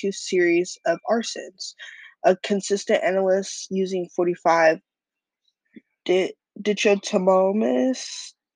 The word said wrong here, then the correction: dichotomous: I can't pronounce that. This word means exhibiting or characterized by to show me on how to pronounce that. It's pronounced dichotomous.